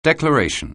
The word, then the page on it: declaration: anglais